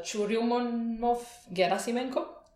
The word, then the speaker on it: Churyumov-Gerasimenko: And yeah, I'm not sure I can pronounce the other names, but Churyumov-Gerasimenko? – Yes, that's right.